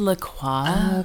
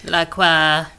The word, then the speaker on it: LaCroix: We're both having LaCroix. – I believe it's pronounced LaCroix. – Okay. You can just go be French on me then. LaCroix.